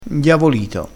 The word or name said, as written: indiavolito